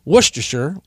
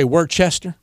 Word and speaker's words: Worcestershire: How do you say it? – Worcestershire. – Worcestershire. – Worcestershire. – Yeah. – Worcestershire. Some people say Worcester. – Yeah. Dad always said Worcester.